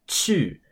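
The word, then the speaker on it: qù: So a super common word with pinyin Q is qu, which is spelled Q-U and in the fourth tone. This is the verb to go. You take the qi sound and turn it into qu, and that means to go.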